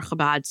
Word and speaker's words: Chabad: We are Chabad.